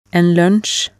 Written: en lunsj

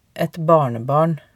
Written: et barnebarn

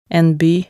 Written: en by

